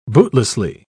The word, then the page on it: bootlessly: British English